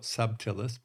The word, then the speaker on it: subtilis: Now, it looks like it's spelled subtilis, but it's pronounced subtilis.